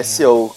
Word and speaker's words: SEO: É SEO que se pronuncia.